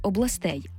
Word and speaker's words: областей: Тільки там виступає наголошене закінчення «ей», «областей».